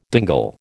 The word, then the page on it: dingle: American English